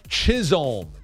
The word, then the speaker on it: Chisholm: Chisholm. Just pronounce the L. That's important there. Jazz Chisholm. Because even if you do with the J and get them mixed up, if you see Chisholm, you save yourself.